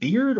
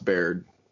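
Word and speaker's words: Beaird: The screenplay was written by John, is it Beard or? – I think that's pronounced Baird. – Baird, yeah, John Baird.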